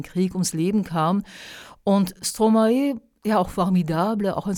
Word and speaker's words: Stromae: Und jetzt, na, es kommt schon Summertime, aber lieber doch habe ich mich für Stromae entschieden. Ist der Sänger, der sich ja in der Umkehrung, in der Vertauschung von zwei Silben aus dem Wort Maestro Stromae nennt. Stromae bekannt für Lieder wie Alors on danse oder Papa Ute, wo er ihm fragt, Vater, wo bist du, Papa, wo bist du?